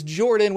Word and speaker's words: Jorphdan: My name is Jordan with a silent PH in the middle,